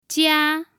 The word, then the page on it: jia: 1